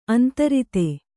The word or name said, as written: ಅಂತರಿತೆ